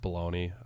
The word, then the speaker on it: bologna: The way it's spelled does not the way you say it, right? Or are we just dumb Americans and we just keep saying it wrong? – I don't. Yeah. Bologna. – Yeah, that's how it should be said.